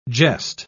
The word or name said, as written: gest